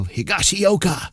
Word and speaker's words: Higashioka: And it's so awesome because they say it with such panache, you know, Higashioka. And, you know, they're not tripping on it. They're not stumbling. They're saying it right.